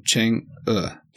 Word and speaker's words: Chang'e: I listened to the Chang'e? – No, Chang'e. – Chang'e. – Yeah, Chang'e. Spelled C-A-N-G apostrophe E.